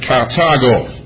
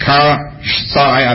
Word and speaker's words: Carthago: I pronounce it, Carthago. I look at the internet and they say you can pronounce it Car-Thai. I don't know how in the world they come to that pronunciation. You spell it C-A-R-T-H-A-G-E. The Latin Greek pronunciation would be Carthago, but that is a famous city.